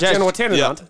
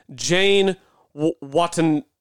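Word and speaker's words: Janewattananond: I still think I pronounced it wrong because I think it's actually pronounced Jazz Jane Watananand. Jane Watananand, I think, is how it's pronounced.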